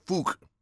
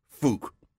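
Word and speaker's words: Phuc: It's Fook. – Fook? – Fook, yes. It's pronounced Fook.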